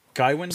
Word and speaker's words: Gawain: I always pronounced it Gawain, but based on Gawain, you're right.